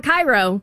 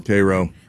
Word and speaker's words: Cairo: Well, and there's the city that looks like it's pronounced like the one in Egypt, but it's not. It's not Cairo. – Oh. – Cairo. – Cairo.